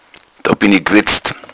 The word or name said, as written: wetzen